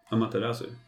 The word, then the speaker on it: Amaterasu: Amaterasu.